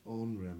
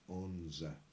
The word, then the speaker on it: onra: Now this onra meaning a petticoat is sometimes pronounced onza. – Oh yes. – If it's any easier for you to say onza or onra, I don't know.